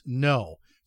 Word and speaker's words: Ngo: Uh, this book is called Unmasked, and it's written by Andy, and the last name you'll see is spelled NGO, but it's pronounced no. So it's pronounced Andy No.